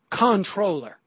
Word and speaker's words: comptroller: And it appears that comptroller is essentially controller and is in fact pronounced controller. She writes, despite the archaic spelling, the word is correctly pronounced controller. – I don't know who straight you wrong. – You did. The pronunciation as controller is unquestionably correct and is usually preferred. The comptroller pronunciation is increasingly common and is variously regarded as an error or as an acceptable new pronunciation.